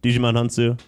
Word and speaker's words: Djimon Hounsou: cillian murphy the gem the Jambison Hunsu. Am I saying that right? Djimon Hounsou.